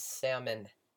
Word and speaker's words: salmon: And my favorite meal is salmon, spelled with the L, which is silent. – It's not really silent, it's just, it's like sword, where it's like just kind of dropped out.